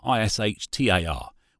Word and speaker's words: Ishtar: worshipped by the pagans from a god called Ishtar, I S H T A R, which they pronounced Ishtar.